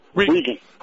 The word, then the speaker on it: Regan: Is it Regan or Reagan? – Regan. – How are you doing, Regan? – I'm good. It's Regan, R-E-G-A-N. There's no A in the middle. It's Democratic spelling, Democratic pronunciation. – Okay, Regan. – Not like a former president at all. – But exactly like the little girl in The Exorcist.